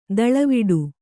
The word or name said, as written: ದೂಳುವಡೆ